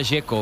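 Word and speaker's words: Džeko: Dzeko. Bruno dice que es Dzeko. – Como tirando a J, Dzeko o algo así.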